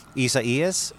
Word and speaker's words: Isaias: Isaias? – Correct.